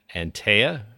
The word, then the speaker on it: Antea: And I'm with Floyd Baker from a company called Antea, which is spelled A-N-T-E-A. So if you're looking, if you're busy Googling them right now, that's how you spell it. But you say it, Antea.